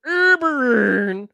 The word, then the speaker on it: Eberron: Uh, let's let's do good old Ebron because I feel like that's one that I am familiar with. – It is pronounced Eberron.